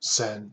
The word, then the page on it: cents: American English